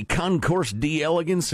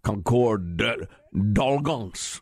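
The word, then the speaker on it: Concours d'Elegance: I've heard people lately trying to pronounce it properly in the French. Don't bother. The Concorde d'Elegance, or whatever that's. It's the Concourse d'Elegance.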